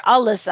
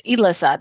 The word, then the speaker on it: Alyssa: And then I have a character named Alyssa with an E, and I didn't want people to call her Alyssa. I wanted her name to be pronounced Elissa.